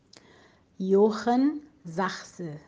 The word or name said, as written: Johann Sachse